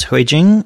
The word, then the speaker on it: Hui Jing: It's Hui Jing.